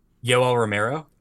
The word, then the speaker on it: Yoel Romero: How do you pronounce his name? Yoel Romero. Oh, I thought you said Go- Gil or something like that. I was like, what? There was no G no, in Yoel. there.